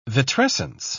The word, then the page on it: vitrescence: British English